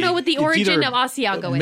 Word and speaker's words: Asiago: But look, what is Asiago? – That is, okay. – Beautiful pronunciation. – Thank you. – It's gorgeous. – Asiago.